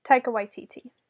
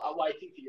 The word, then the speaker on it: Taika Waititi: And that's how I knew it was the movie by, how do you pronounce his name again? – Taika Waititi. – Oh, Waititi.